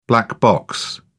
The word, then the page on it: black box: British English